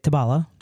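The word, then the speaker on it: tobalá: Well, I was corrected when I said it like that. So I say it, tabala. – I mean, tabala is probably how you're supposed to pronounce it. – Well, there's an accent on the... an acute accent on the final A.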